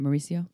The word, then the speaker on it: Maurizio: Maurizio? – Mm-hmm.